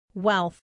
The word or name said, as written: wealth